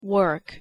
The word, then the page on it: work: English